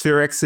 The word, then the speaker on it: SPHEREx: First of all, am I saying that acronym correctly? – Yes, SphereX is the way we pronounce that acronym.